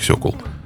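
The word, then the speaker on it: Sokół: «Сокол 1000 М111». По-русски читается как "Сокол".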